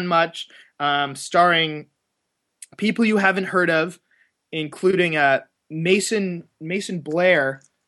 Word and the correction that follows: Macon Blair: I believe his name is, who is the... – Macon Blair is his name. – Macon Blair, that's how you pronounce it, who's the lead of this.